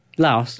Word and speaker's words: Laos: Laos.